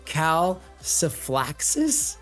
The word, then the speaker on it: calciphylaxis: It's something called CALCIPHYLAXIS. Yep. Did I say that right, Lexi? – Yeah, that's okay. Right.